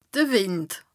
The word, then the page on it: de Wìnd: Bas-Rhin; Strasbourg